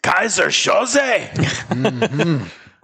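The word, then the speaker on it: Keyser Söze: No, you pronounce it like the Hungarian guy does on the tanker at the end. Kaiser Soze.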